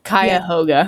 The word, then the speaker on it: Cuyahoga: I'll help you out here. Cuyahoga. Yeah. – Thank you. Cuyahoga River. I was trying so hard. – That one's a difficult one. I mean, it's phonetic. It's pronounced the way it's spelled, so it is phonetically spelled, but it's still not pleasant to look at.